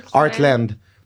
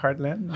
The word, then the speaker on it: Heartland: Comment tu prononces ça? Art? Art, ouais. Heartland. h e r t ouais. Heartland.